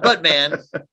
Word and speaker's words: Batman: Not Buttman, Buttman. But it's spelled B-A-T-M-A-N.